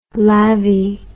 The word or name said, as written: lavvy